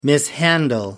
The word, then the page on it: mishandle: American English